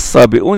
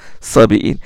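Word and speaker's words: الصابئون: Il doit se prononcer sabi'un et non pas sabi'in.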